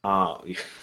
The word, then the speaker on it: Beldre: I don't even know how to say her name. Beldra. I'm just going to call Beldra. – Beldre. – I haven't listened to the graphic audio one for this, so I don't know how it's pronounced.